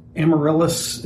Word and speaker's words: Amaryllis: It is hosted by a former CIA agent, Amaryllis, is that how we say it? – Yes. – Amaryllis Fox. – It's spelled in such a way that it makes my mind struggle to say it, but I believe it's Amaryllis.